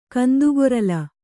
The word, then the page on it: ಕಂದುಗೊರಲ್: kannada